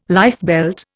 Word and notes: lifebelt: Indian

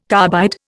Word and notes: carbide: Indian

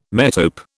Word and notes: metope: British English

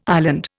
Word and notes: island: Indian

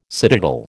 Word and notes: citadel: American English

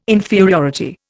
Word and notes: inferiority: Indian

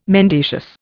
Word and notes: mendacious: Indian

